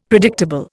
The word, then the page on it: predictable: Indian